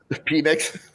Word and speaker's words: Penix: Penix. – Hey, you're on a roll today. – No, it's always Penix to me. That's how you pronounce it.